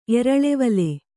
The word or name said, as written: ಎರಳೆವಲೆ